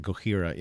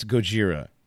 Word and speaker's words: Gojira: Gojira, yeah. But it's Gojira, dude. That's how they pronounce it. That's how the band says it. That's how it's spelled with a J. It's Gojira.